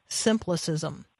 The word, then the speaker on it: simplicism: Jeff, let's talk about this this word in here that I may mispronounce, and so forgive me if I do. Simplicism. What is simplicism, and how is it different than simplicity? – Well, you pronounce it exactly right, I think, because I think I coined the word.